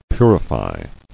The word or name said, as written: Purified